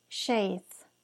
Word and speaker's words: Seth: Seth en hebreo se pronuncia Sheith su nombre en inglés significa compensation, o sea, compensación, reemplazo o sustituto.